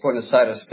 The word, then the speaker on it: Buenos Aires: Yes, Buenos Aires. That's how it's pronounced here.